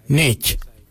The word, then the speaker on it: ngeidj: Now let's take that out, D-J-I-L, and let's put another word, which is Y-A-N-G, Nganyang. Nganyang means give me. For I, we say Nech. Nech is spelled N-G-E-I-D-J. Nech, I.